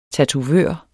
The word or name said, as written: tatovør